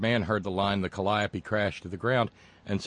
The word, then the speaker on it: Calliope: Of course, we all know that thanks to Anderson Vanderbilt Cooper, the proper pronunciation is Calliope.